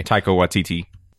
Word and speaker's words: Taika Waititi: Waika Takiki, or how do you say the guy's name? Anyway. – Taika Waititi.